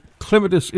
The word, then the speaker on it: clematis: The other thing you face, particularly, you know, with things like clematis, clematis is the correct pronunciation.